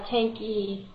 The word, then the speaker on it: Tanke: It's actually a uh, Tanky. – Tanky. You, it's not a silent E? – No.